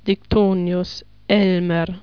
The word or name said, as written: Diktonius, Elmer